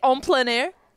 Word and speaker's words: en plein air: plein air. – I'm trying to say it in French, how they say it. En plein air.